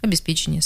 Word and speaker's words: обеспечение: В отглагольных существительных, где у глагола было ударение, там оно в отглагольном существительном осталось. Обеспечить, обеспечение, соответственно.